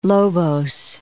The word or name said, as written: Lobos, Point